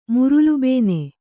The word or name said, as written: ಮುರುಲುಬೇನೆ